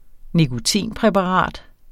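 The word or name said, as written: nikotinpræparat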